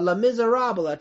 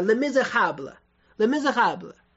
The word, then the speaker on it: Les Misérables: 所以而且《悲惨世界》其实这本书啊，这英文当中讲叫 Les Misérables，这个是不对的，应该叫 Les Misérables 悲惨世界。